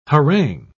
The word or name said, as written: harangue